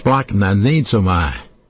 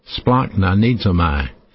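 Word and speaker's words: splachnonizomai (σπλαγχνίζομαι): It's kind of hard for me to pronounce, but it's called splachnonizomai. Splachnonizomai.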